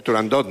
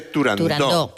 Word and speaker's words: Turandot: Turandot no, se dice Turandot, que es... – Como lo pronunciaba, Puccini y como lo pronunciaba Toscanini.